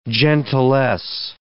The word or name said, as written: gentilesse